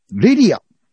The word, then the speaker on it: レリア: 本日最後にご紹介する 5 作目はですね、九州は宮崎出身のハイブリッドガールズロックバンドレリア。レッドイットアウトと書いてレリアと読ませる。